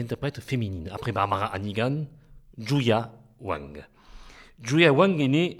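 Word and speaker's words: Yuja Wang: Au nom impossible de Zhu Ya Wang, et je prononce comme l'a dit mon ami le compositeur, c'est toi Jean-Jacques Dituxi, Zhu Ya Wang, une pianiste absolument extraordinaire.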